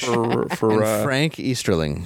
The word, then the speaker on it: Frank Easterling: Frank Easterling.